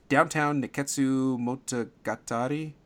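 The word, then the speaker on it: Downtown Nekketsu Monogatari: Let me see if I can get this. Downtown Neketsu Monogatari. – Nailed it.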